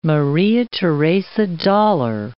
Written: Maria Theresa dollar

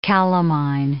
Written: calamine

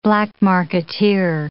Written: black marketeer

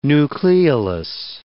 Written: nucleolus

